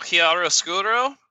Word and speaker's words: chiaroscuro: Oh, it's a real chiaroscuro. Yeah, I pronounced that word correctly.